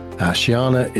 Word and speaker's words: Chiana: Shiana is spelled C-H-I-A-N-A.